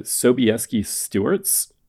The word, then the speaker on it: Sobieski Stuarts: So he and his brother James were known as the Sobieski Stuarts, if I'm saying that right.